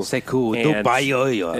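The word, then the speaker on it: Sekou Doumbouya: Sekou, Dubayoyo. – Exactly. That's exactly how you pronounce it, actually.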